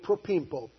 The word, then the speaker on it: propempo: If we were to spell it in English, it's the word propempo. P-R-O-P-E-M-P-O.